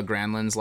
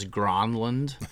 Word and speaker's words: Granlund: Anyway, he always pronounces Marcus and Mikhail Granlund's last name as Gronlund. And that's always driven me crazy, so I hear you on that.